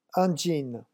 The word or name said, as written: un jean